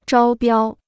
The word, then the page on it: 招标: zhāo biāo